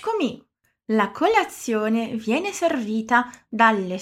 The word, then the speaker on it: dalle: E aqui repara que temos duas consoantes duplas, dalle e alle. Lembra o que acontece com elas? A gente pronuncia de maneira mais demorada, mais alongada, igual o TT da palavra notte, que a gente acabou de ver.